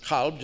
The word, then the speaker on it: kalb: Another possible Hebrew etymology is Hebrew kalev, dog, originally pronounced kalb, just as it is in Arabic.